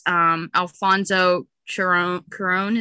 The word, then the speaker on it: Cuarón: It's Alfonso Cuarón. Is that how you say it? I always say it wrong. – Yeah, I think so.